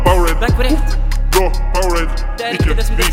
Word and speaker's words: Powerade: Engelsk. Ja, Powerade, Powerade. Det er sånn det uttales.